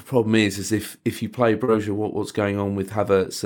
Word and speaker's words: Broja: Broja.